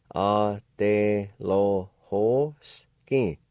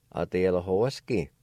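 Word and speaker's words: adelohosgi: Word number four, conscious. It's pronounced like this. A de lo ho ski. A de lo ho ski. A de lo ho ski.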